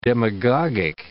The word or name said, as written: demagogic